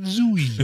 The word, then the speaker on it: Zooey: And does she really pronounce it Zooey? – Ooh, yes.